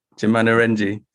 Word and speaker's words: Jama Narangji: Forgive me for my pronunciation. Is it okay? Jama Narang? – Jama Narangji.